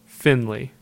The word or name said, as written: Findlay